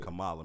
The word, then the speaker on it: Kamala: It's Kamala.